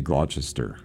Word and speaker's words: Gloucester: So the Earl of Gloucester, now it is spelled G-L-O-U-C-E-S-T-E-R. Don't ever say Gloucester. It's not the right way to say it. It's just Gloucester.